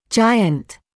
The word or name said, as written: giant